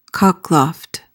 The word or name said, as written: cockloft